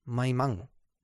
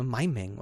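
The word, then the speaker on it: Maimang: Mai Mang. Ja. man will das ja unbedingt Englisch aussprechen. Ne? Mai Meng oder Mai Meng oder sowas. Ne? Aber nein, Mai Mang.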